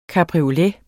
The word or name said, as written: cabriolet